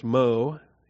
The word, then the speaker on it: Meaux: I do know that Meaux is pronounced Meaux, M-E-A-U-X,